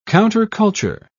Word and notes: counterculture: British English

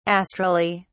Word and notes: astrally: British English

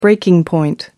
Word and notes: breaking point: American English

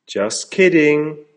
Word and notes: just kidding!: American English

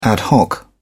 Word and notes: ad hoc: British English

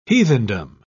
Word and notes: heathendom: British English

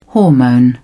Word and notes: hormone: British English

